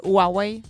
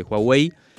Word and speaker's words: Huawei: No se dice Huawei. – No, se dice Huawei. Hua, con acento en la A y con J.